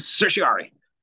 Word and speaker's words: certiorari: And most people just really shorten it down to writ of certiorari. They just bunch all those vowels together in R's. – All right. – Writ of certiorari.